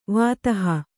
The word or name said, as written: ವಿದೇಹ